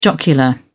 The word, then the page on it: jocular: British English